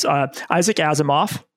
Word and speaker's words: Asimov: I'm going to say his name and you're going to correct me on the correct pronunciation. So it's Isaac Asimov, which I think I nailed. – I think now. You got it right.